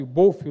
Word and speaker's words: Boff: Essa frase é, é, acho que é Leonardo Boff, Boff, o nome que se pronuncia, sobre isso.